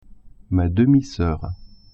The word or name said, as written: demi-soeur